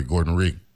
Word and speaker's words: Gordon Rhea: Gordon Rhea, yeah. Joining us. – You said it right that time.